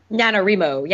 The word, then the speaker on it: NaNoWriMo: lately with things like NaNoWriMo. Is that how you say it? – NaNoWriMo. Yes, the November novel writing month.